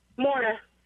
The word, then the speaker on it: Mara: Mara. – Okay, so who's going to win this? – Like Mara, but with an M.